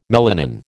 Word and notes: melanin: American English

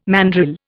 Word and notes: mandrill: Indian

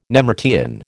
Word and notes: nemertean: American English

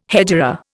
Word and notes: hegira: Indian

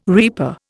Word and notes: reaper: Indian

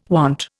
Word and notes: want: Indian

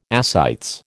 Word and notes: ascites: American English

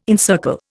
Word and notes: encircle: Indian

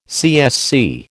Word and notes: CSC: American English

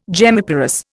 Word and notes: gemmiparous: Indian

